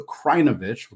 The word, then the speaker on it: Krajnovic: So Luka Krajinovic, we'll get that correct.